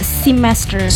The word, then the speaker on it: semester: Kalau dari pronunciation yang dari bahasa Inggris yang benar itu ada semester. – Semester. – Ya, jadi kayak E itu dibaca I kalau dalam bahasa Inggris, jadi kayak semester.